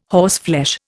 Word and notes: horseflesh: Indian

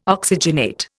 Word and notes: oxygenate: Indian

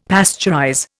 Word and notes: pasteurize: Indian

